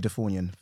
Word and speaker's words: Ndifornyen: No, because what it is, my name's AKEMNJI, AKEMNJI NDIFORNYEN, for people who can't pronounce or don't know. – Can I pause? Because I cannot. – Yes, so AKEMNJI NDIFORNYEN, that's a name from Cameroon.